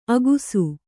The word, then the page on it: ಅಗುಸು: agusu